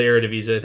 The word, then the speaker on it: Eredivisie: Yeah, the Eredivisie.